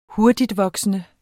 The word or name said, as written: hurtigtvoksende